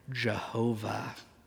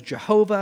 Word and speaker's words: Jehovah: You see, again, the squiggly lines I said below, the vowels, they pulled the vowels under from Adonai, put them in on the divine name, and then it comes out with the pronunciation, at least in the Latin at the time, Jehovah. How many of you guys have ever been taught that God's name is Jehovah?